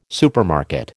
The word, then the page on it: supermarket: American English